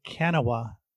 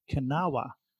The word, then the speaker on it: Kanawha: I named it what I thought the Kanawha Anomaly, named after a region in West Virginia. And I was bragging about it on Bagel Bards with some folks. And one of the guys said, I hate to tell you this, but it's pronounced Kanawha. It's a Native American name.